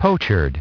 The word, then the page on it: pochard: British English